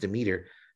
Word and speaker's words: Demeter: I didn't know it was pronounced Demeter. And she just matter-of-factly tells me, oh yeah, that's how it's always been pronounced.